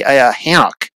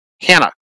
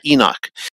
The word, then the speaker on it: Hanok: For example, you know, Enoch is, if I'm pronouncing it right, it should be Hanok. Hanok, not Enoch.